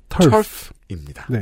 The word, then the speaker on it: TERF: T E R F. – 털프입니다.